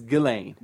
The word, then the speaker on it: Ghislaine: It's Ghislaine.